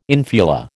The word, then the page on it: infula: American English